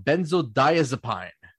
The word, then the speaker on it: benzodiazepine: The other one, the one that a lot of people have trouble pronouncing, called benzodiazepine.